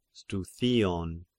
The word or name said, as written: Struthion